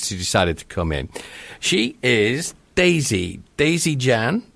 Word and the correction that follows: Zhang: Did I pronounce that correctly? – Daisy Zhang, but I think you pronounce pen fantastic.